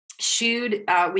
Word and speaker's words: Shood: And for our listeners, I'm spelling that, or it is spelled S-H-O-O-D, Shude.